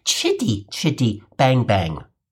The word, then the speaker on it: Chitty Chitty Bang Bang: Just so you're aware, here in America, for words beginning with the letters C, H, we use the ch sound, not the sh sound. So the proper way to say the title of the film you saw over the weekend is Chitty Chitty Bang Bang."